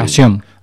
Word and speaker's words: ASION: O Asión, no sé si dice. – Asión. – Asión. – Yo la pronuncio aguda. – Muy bien, pues hay que ponerle la tilde.